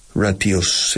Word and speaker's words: ratio: Ratios, se dice aquí ratios. En inglés ratio, SH muy fuerte, sonido SH, como ella, she.